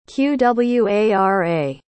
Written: qwara

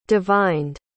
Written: divined

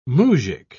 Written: muzhik

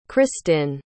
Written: christin

